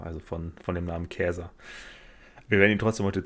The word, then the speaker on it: Caesar: Also Caesar, wir sprechen es heute Caesar aus, äh, wird eigentlich gar nicht Caesar ausgesprochen, denn das lateinische C wird wie ein K gesprochen. Dann heißt es ja schon mal Käser